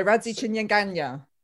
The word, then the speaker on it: Radzi Chinyanganya: Radzi Chinyanganya? – Ganya. Yeah. – So Radzi Chinyanganya. – Should we go for the full name as well? – Okay. – So it's Munya Radzi Chinyanganya.